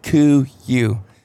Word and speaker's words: Kuiu: it's Kuiu.